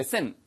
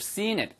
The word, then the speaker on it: seen: 짧게 발음하시면 seen it. 이게 sin. 이거는 뭐예요? 죄를 저지르다 죄란 뜻이고 you should have seen it. 그렇게 길게 발음하시면 바로 보았었어야만 했는데 물어봤어야지 나한테 먼저 you should have asked me.